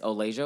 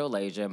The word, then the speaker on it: O'laysia: So you can find me on Twitter as O'laysia O'laysia. My name is spelled O-L-A-Y-S-I-A.